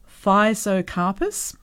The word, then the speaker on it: Physocarpus: Nashiki Red and Orange Physocarpus, have I got that right? – Yep.